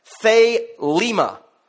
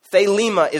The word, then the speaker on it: Thelema: I'm not even sure what that means, but this is how what that word is pronounced. Thelema. Thelema is that word there, and here's the definition.